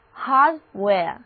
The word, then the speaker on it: hardware: W-A-R-E well 的意思是器皿。那注意字母组合 A-R-E 在这里的发音是 air，所以念作 wear， hardware。